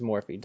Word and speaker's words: Morphe: they said Morph, not Morphe. – No, it's Morphe.